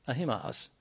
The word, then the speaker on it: Ahimaaz: And so this passage, and here we come to verse 19, Ahimaaz. Is that how you say it, Milton? Ahimaaz? Ahimaaz.